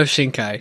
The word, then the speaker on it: Shinkai: We're talking about Your Name this week, our second of the three Shinko. Did I say that right? – Makoto Shinkai. – Shinkai. The Shinkai films are second of our trilogy that we are reviewing.